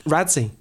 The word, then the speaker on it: Radzi: On the third day, um, I was Radzi. Oh, and then it stuck, God. and it should be pronounced uh, radzi, so it should be an ah rather than a pure ah sound.